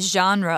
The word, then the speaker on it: genre: So, we're talking about it as a genre of hip hop dance, and you might realize that that word genre is pronounced in a different way than you might expect. That's because it's actually French, but we use it in English too.